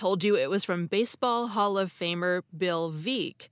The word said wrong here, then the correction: Bill Veeck: but his name is actually pronounced Bill Veeck. The mispronunciation is so common that the title of his autobiography is Veeck as in Wreck.